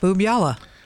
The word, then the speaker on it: Boobyalla: Boobyalla. Boob. I don't know if that's the way you're supposed to pronounce Boob-yalla.